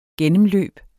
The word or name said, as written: gennemløb